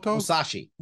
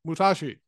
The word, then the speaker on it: Musashi: Musashi. – Musashi.